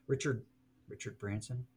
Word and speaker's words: Richard Branson: I like what Richard Branson.